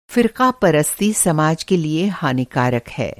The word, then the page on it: फ़िरक़ापरस्ती: فرقہ پرستی